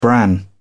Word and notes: bran: British English

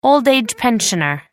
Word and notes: old-age pensioner: American English

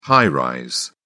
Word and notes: high-rise: British English

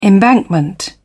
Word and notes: embankment: British English

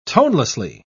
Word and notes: tonelessly: British English

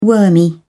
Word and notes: wormy: British English

